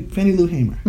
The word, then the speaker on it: Fannie Lou Hamer: Uh, I'm sorry, Fannie Hamer. Um, did I get that right? Fannie Fannie Lou Hamer. Mm-hmm. Right, Lou. I knew there was a third syllable I was missing.